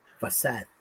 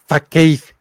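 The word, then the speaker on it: Fasaud: Fasad. – Fasad. Yo antes pronunciaba Fakeid. Hasta que así dije, no tiene nada que ver mi pronunciación toda culera. Fasad.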